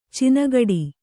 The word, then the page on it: ಚಿನಗಡಿ: cinagaḍi